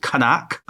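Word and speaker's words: Qaanaaq: They relocate the Inuit inhabitants about 60 miles north to a town called Kanaak. I think that's my Inuit pronunciation. – That's good pronunciation. Solid B+.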